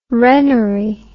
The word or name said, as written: wrennery